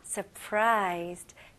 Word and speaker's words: surprised: 여기서는 S, 두 번째 S 발음을 약한 Z 발음으로 하시면 좋아요. Surprised, surprised.